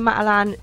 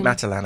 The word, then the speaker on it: Matalan: A tiny bit of traffic near Matalan in those traffic lights there. – Say Matalan again. – Matalan. – No, you didn't say it like that. – What did I say? – Matalan.